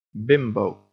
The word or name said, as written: bimbo